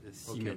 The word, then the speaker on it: Siemens: Alors c'est S-I-E-M-E-N-S. – Ah d'accord, donc c'est Siemens, ok. – Siemens. Okay. Alors, la prononciation allemande est plutôt Siemens.